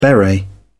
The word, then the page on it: beret: British English